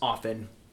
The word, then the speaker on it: often: So that word you just use, often, did you know the English language, you're actually supposed to not pronounce the T? It's often. – Often. – No, it's not. – There's a letter there. – There is a letter, but it's not how it's supposed to be pronounced.